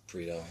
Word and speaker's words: Preto: It's Preto.